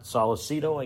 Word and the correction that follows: Savastano: I guess that's how it's pronounced. Savicito, I believe. – Is it Savastano? – Savastano. – There you go. – Savastano, uh-huh.